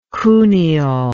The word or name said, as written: Cuneo